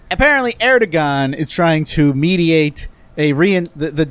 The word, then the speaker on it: Erdogan: Also, the G is pronounced like a W. It's Erdogan.